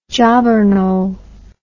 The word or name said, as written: jobbernowl